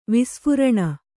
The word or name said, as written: ವಿಸ್ಫುರಣ